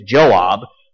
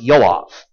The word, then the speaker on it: Yoav: His name, we would look at his name and say his name is Joab, but in Hebrew it's pronounced Yoav.